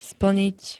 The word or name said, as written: splnit